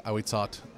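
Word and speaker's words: Ahuitzotl: My native speaking friend told me that's how it's pronounced, but I was saying Ahwitzotl because that's how it's pronounced. – Oh yeah. – But I guess it's that's how it's spelled. Apparently, it's pronounced Ahwitzot.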